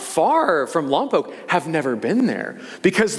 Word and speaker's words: Lompoc: I mentioned earlier I grew up a little north of here in the booming metropolis of Lompoc, California. And I can, by the way, that's how it's pronounced, okay? Stop saying Lompoc, all right? It's Lompoc.